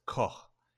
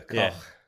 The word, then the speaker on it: Koch: But what I thought Cock can should point out that it's pronounced Koch, and you use the H as in from the word home. So we do understand that. that you pronounce it like that. – So Koch.